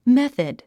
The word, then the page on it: method: méθəd; メセド